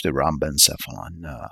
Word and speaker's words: rhombencephalon: The hindbrain or rhombencephalon Is that rhombencephalon or rhombencephalon? I can't quite recall. I'm going to double check the pronunciation right now. Yeah, it's pronounced rhombencephalon on Google Translator.